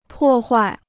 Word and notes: 破坏: pò huài

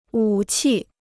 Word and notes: 武器: wǔ qì

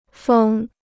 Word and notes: 封: fēng

